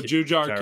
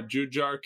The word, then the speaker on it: Jujhar: From Edmonton, Juhar Kyrra. Kyrra? – Oh, Jujhar Kyrra. – Is that his name? Are you serious? That's how you say his name? Jujhar? – Yeah, Jujhar Kyrra.